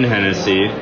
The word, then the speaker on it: Hennessy: Wayne Hennessy. – Okay, so let's. Okay. – Oh, like the whiskey. – Do you have the lineup? – Yeah, I'm lying. Okay. Number one, Wayne Hennessy.